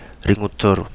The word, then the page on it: Ringutoru: Kapingamarangi